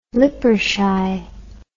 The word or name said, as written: Lippershey